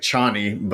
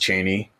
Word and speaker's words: Chani: Which it looks like Chani, but it's pronounced Chaney.